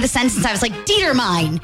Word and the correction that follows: determine: And my teacher's like, no, honey, that's determine.